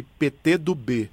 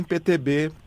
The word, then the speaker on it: PT do B: Meu produtor me lembra para pronunciar corretamente aqui PT do B, para não ficar nem isso, parecendo PT nem PTB, PT do B.